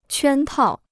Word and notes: 圈套: quān tào

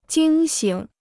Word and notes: 惊醒: jīng xǐng

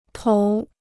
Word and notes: 头: tóu

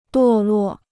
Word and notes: 堕落: duò luò

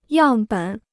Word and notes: 样本: yàng běn

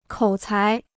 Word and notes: 口才: kǒu cái

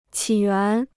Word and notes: 起源: qǐ yuán

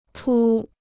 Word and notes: 除: chú

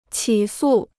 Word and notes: 起诉: qǐ sù

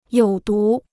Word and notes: 有毒: yǒu dú

